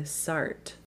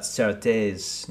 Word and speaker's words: Sartre: So we find out that Norma is a teacher, and we go to her classroom where she's teaching Sartre. – Yes. – Yes. – I once had a very pretentious professor pronounce it Sartre. We're going to talk about Sartre's No Exit, but now I think it actually is Sartre.